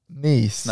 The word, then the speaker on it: Nice: I've been to Nice. – Nice. – Nice. – Okay. – The hell is that? – So that's how you pronounce it.